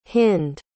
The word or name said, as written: hynd